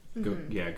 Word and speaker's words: Gauguin: Like I was saying with Gauguin, am I saying that right? – Gauguin.